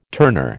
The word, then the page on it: turner: American English